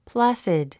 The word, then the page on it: placid: plǽsid; プラァスィッド